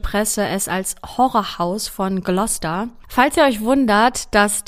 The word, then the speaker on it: Gloucester: Also sie heißt Gloucester, wird aber eher Gloucester geschrieben, also nicht wundern an dieser Stelle.